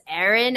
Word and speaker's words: Erin: Simon, E-R-I-N as in Nancy, the letter A-S-I-M as in mother, O-N as in Nancy. And no, my name is not Arena. I know people see that A. It is Erin Ashley Simon.